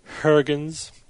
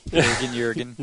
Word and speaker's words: Huygens: You think we'll ever pronounce his name correctly, though? – That's Christian Huygens. – Huygens. Jürgen. – No, it's wrong country.